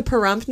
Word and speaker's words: Pahrump: So in 2005, she moved to Pahrump, Nevada, to open a much publicized, quote-unquote, stud farm for women willing to pay for sex.